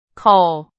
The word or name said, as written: 口